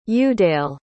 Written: udale

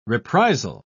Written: reprisal

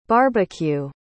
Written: barbeque

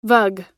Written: vug

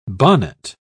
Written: bonnet

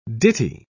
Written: ditty

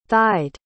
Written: thighed

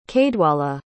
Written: caedwalla

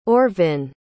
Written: orvin

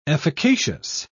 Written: efficacious

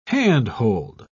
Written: handhold

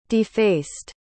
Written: defaced

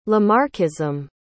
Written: Lamarckism

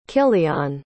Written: kylian

